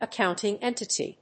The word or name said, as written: accounting entity